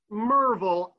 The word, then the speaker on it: Maryville: For the purposes of this story, it doesn't matter which school or where, except that we were not in Maryville, Tennessee, or Merville, as the locals say, if we were in Merville, but we weren't.